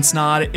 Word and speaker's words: Snod: My name is Ryan Snod. It rhymes with odd, and you're listening and watching the Rhymes with Odd podcast.